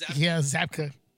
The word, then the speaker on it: Zabka: William Zabka. I said his name wrong last week. – Oh, that's what it is. – Zabka.